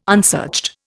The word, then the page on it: unsearched: Indian